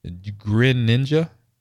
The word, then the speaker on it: Greninja: Grin. Oh, excuse me. Greninja.